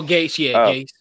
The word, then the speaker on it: Gase: I have a whole bunch, though, but I'm definitely saying Adam Gase. I think I said his name right. Adam Gase. – Oh, Gase, yeah.